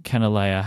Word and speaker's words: Kanilea: Um, and I also bought some uh, Robert Oster Yellow Sunset ink, which is for my Kanilea Haleakala silhouette.